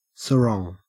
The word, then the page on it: sarong: Australian English